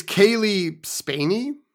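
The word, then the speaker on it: Cailee Spaeny: And it's Kaylee Spaney.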